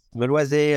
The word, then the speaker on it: Meloisey: I'm going to first ask you about your background because you grew up in the Haute Côte de Beaune in Meloisey, right? Did I say that right? – That is correct. Yes. Meloisey,